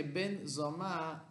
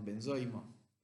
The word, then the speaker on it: Ben Zoma: No sé si todos tienen Pirkei Avot en castellano delante de ustedes para seguirlo, pero así empieza que Ben Zoma dice. Ben Zoma quiere decir el hijo de Zoma yo le pongo el acento en la A, por ahí se llama benzoma. En yiddish diríamos benzoimo.